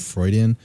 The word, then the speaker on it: Freudian: He set such a high standard with Freudian? – Freudian. – Is that how you pronounce it? Freudian. Yeah, he set a really high bar with that.